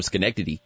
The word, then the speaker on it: Schenectady: Like when Ricky Hatton fights Floyd Mayweather, and all of a sudden people are like Ricky Hatton's from Schenectady. – Mm-hmm. – Did I say that right? – Yes, you said Schenectady correctly.